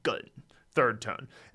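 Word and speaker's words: gun, third tone (钝): And actually, when it means blunt, it's pronounced gun, third tone.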